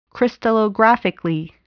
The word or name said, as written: crystallographically